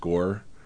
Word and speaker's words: Ngor: But it's spelled N-G-O-R, but it's pronounced just Gore.